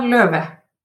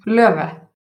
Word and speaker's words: Löve: So it's pronounced Kolbrun Thora Löve. – Okay, I would never have gotten that from what I saw. – No. – Wait, pronounce the last name again? – Löve.